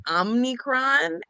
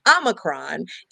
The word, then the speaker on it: Omicron: But Omicron, which for a long time I thought was Omnicron, and so I was just saying it wrong, and then I heard Rachel Maddow say it right, and then like I was shamed into saying it correctly.